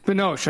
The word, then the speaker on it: Binoche: B-I-O-N-C-H-E? I don't know how to say it. – No. Binoche. Binoche? – Binoche. I think that's how you say it, correct.